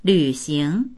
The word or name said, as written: lv3xing2